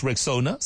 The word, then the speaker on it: Rexona: It's pronounced Rexona, simple, strong, and easy to remember.